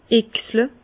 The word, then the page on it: Oechslé: Bas Rhin; Herrlisheim